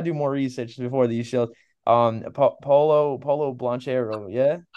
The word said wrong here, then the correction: Paolo Banchero: Paolo Banchero.